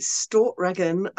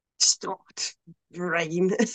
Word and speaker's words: Stortregn: There's an album by the Swiss tech death / black metal band called, I pronounce it Stortregen. I think the actual pronunciation of it has like a glottal stop in it, so it's like Stort Rain.